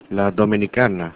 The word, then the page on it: dominicana: Spanish